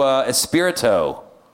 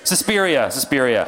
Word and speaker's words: Suspiria: I went to a screening of the new Espirito horror film. You know about that? They did a remake of this 1960s horror movie that's about to come out. Am I saying that wrong? Suspiria.